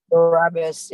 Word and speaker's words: Barabbas: He writes, we have become like the crowd chanting Barabbas. Is that how you say it? For the Barabbas, yeah.